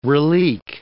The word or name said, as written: relique